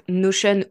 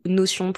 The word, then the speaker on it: Notion: pas tellement sur la question en elle-même, mais sur la réponse, parce que donc Notion ou Notion pour les gens qui le prononcent à la française.